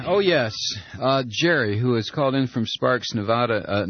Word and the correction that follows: Nevada: Nevada, I'm sorry. I pronounce it like Bush, and I shouldn't. Sparks, Nevada.